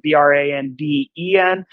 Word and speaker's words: Branden: My name is, notice that it's spelled Branden, mm-hmm.